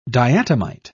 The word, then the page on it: diatomite: British English